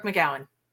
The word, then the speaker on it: McGowan: Brooke McGowan.